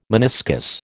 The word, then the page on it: meniscus: American English